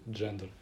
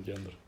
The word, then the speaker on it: гендер: Александр Викторович, по-моему, Марков, из РГГУ в своей лекции говорил, что на русском правильно говорит «джендер», а не «гендер».